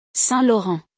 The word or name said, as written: Saint Laurent